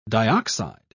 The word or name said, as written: dioxide